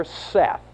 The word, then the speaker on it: saith: Verse 5, his mother, Seth, and that's how that word is pronounced, remember, S-A-I-T-H is not saith.